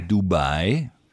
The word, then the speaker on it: Dubai: Ach, da fliegen Sie über Dubai. Achten Sie darauf, dass ich auf der zweiten Silbe betone.